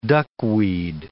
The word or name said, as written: duckweed